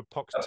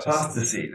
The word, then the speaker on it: apostasy: Apostasy, yeah. Or apoctasy? – Apostasy. That's the apostasy.